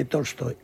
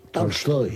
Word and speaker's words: Tolstoy: No es exactamente Tolstoy, es Tolstoy. Tolstoy. – ¿Cómo? ¿Ah? Lo dije con Tolstoy, con A, una... – Embajador, Tolstoy. Lo dije Tolstoy. Así tal cual, Tolstoy. – Correcto, es una es una A que tira hacia el O, pero que es A.